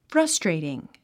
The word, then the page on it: frustrating: frʌ́streitiŋ; フラストレイティン